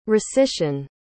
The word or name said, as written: recission